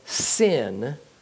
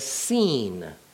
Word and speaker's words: Sin: Now notice I pronounced the name of that desert they moved into not as Sin, S-I-N, Sin, rather as Seen, S-E-E-N.